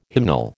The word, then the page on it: hymnal: American English